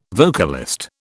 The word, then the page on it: vocalist: British English